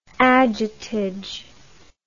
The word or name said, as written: ajutage